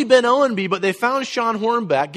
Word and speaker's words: Sean Hornbeck: Did I say his name right? Yeah, it's Sean Hornbeck.